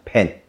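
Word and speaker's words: Pan (팬): 那对于韩国人来讲 F 这个 F 的发音比较难啊，所以在韩国都会讲 Pan。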